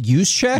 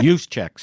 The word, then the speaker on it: Juszczyk: He's kind of, he's young, but they've expanded his role, and he's sort of like, let's see if I say it right, the guy in San Francisco, Jurcik. – Oh, is it Juszczyk? – Juszczyk, sorry. I knew it. Kyle Juszczyk.